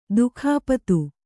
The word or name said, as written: ದಿಕ್ಪತಿ